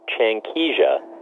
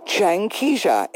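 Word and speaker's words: Changkija: Changkeisha, just like it looks. – Oh, wait a minute. It doesn't, say that again? – Changkeisha. – Changkeisha. It actually is just the way it looks.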